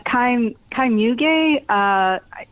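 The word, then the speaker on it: Kai Mügge: Is it Kai Mug? – Yeah. Kai Muge. He's German. So, it usually has an umlaut and I spell it with an E. It's Muegge. Yes, Kai is, he's a German medium, and he's probably the most controversial medium working today.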